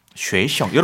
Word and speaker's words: 学生: 쉐이션. 여러분, 네. 성조 들리시죠? – 네. 단단. – 쉐이션. – 단단. – 몇 성인가? 이성. – 올라갔다가 경성. 가볍게. – 이성, 경성. – 네. – 쉐이션.